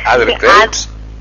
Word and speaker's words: Adler Kreutz: ¿Es Adler Kreutz? – Adler Kreutz. – Es como digo yo, hay que partir el apellido en dos apellidos y listo.